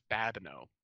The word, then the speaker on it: Babineau: Because, you know, my last name is Babineau.